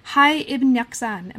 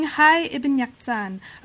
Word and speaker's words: Hay Ibn Yaqzan: and the other is Reading Hay Ibn Yaqzan. Am I pronouncing that right? – That's right, yes. – Okay, so Reading Hay Ibn Yaqzan, A Cross-Cultural History of Autodidacticism, and that also came out with Hopkins University Press, this time in 2011.